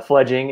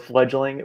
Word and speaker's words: Fledging: First of all, I appreciate you saying it right. Fledging is what we are. A lot of people go with fledgling.